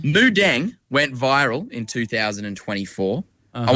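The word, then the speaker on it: Moo Deng: Moo like a cow. D-E-N-G. Mudeng. – Mudeng. Okay. Yep. Okay. Now, Mudeng. – You tell me you know Mudeng. – I have a Mudeng shirt.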